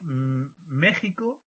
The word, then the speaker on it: México: Sí, mirad, México, según la RAE, conviene escribirlo con J, perdón, con la X, pero se pronuncia con J, ¿de acuerdo? Y conviene escribirlo con la X. Lo que pasa es que lo escribo con J, siempre lo escribo con J, es por costumbre. Pero la RAE dice que sí, que es preferible escribirlo con X y pronunciarlo con J.